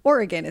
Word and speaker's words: Oregon: We have offices in Portland, Oregon, and Oregon is how you say that.